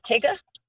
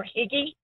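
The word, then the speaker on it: hygge: It's pronounced Tigga or Higgy.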